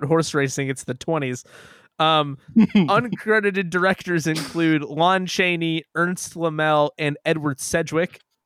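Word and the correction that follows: Laemmle: It's pronounced Lemley. The Lemleys are like a Hollywood dynasty.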